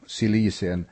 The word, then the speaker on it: Cilicien: Apostlarna och de äldste och bröderna hälsar de bröder i Antiochia och Syrien och Cilicien, eller Kilikien, det finns båda uttalen, som är av hednisk härkomst.